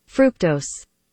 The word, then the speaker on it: fructose: Here is the pronunciation. – Fructose.